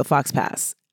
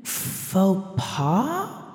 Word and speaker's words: faux pas: And he said, it's a real Fox pass. And she was like, do you mean faux pas? – Oh, I suppose he did. Yeah. – And he was like, Is that how you pronounce it? – And she spelled it on a napkin.